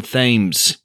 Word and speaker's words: Thames: The stars are the likes of McKenna Grace and Kyler Kaufman and Mason Thames. Yes, I finally learned how to correctly pronounce his name, and it's not Like the River, who anchors things wonderfully as the lead character, Tommy.